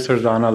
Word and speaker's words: Sardana: Sanjay Sarjana. Last name is spelled S-A-R-D-A-N-A.